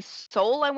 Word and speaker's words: Soule: So the team, the producer of the audio, I wanted to jump into that really quick, is Jeremy Soule, is how you say it, I believe.